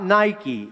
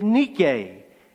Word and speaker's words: Nike: Not Nike, even though it is spelled the same way. "Her name is Nike.